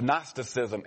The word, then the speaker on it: Gnosticism: The G is silent, they tell me. So, but in Uganda it wouldn't be silent, all right? Amen. But it's Gnosticism.